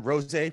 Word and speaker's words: RosA: RosA Productions? – Yes.